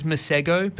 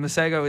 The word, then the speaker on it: Masego: I've seen it as Masego, but that's not right. It's Masego. Yeah. I've seen him pronounce it and I'm taking his word.